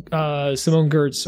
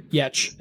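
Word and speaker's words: Giertz: And, you know, people like, you know, we mentioned Adam Savage earlier, like Adam Savage, Simone Gertz, or Yetch, if you say it properly,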